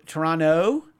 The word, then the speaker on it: Toronto: Toronto. You don't pronounce the T. – Is there a T though? – There is a T. But that's Toronto. It's like New Orleans. You can't say New Orleans.